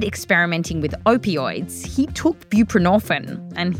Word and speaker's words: buprenorphine: It's called buprenorphine.